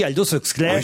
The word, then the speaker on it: Aldous Huxley: Alors, pour une fois, pour le coup, je sais le prononcer, on a toujours dit Aldous Huxley.